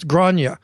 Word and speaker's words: Grania: It's Irish, so it's Grania.